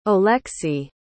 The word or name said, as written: Oleksiy